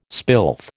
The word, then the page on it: spilth: American English